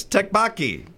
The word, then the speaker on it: tteokbokki: Yes, tteokbokki.